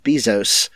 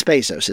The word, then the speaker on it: Bezos: I don't know if you've been following her, but she and Jeff Bezos, or is it Bezos? I always said it wrong. It's Bezos, isn't it? – Anyway, it's like Pat Theos and Paytheos.